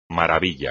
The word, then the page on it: maravilla: Spanish